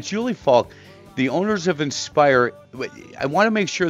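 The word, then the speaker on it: Inspier: Want to make sure because I keep I, it's it's pronounced inspire, but the spelling's a little bit different.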